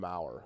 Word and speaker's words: Maurer: Ryan Buchter, Travis Wood, Trevor Cahill, Matt Strom, and Brandon Maurer. Who was on which team? – Well, I just learned yesterday that Brandon Maurer, the first R in his name is silent.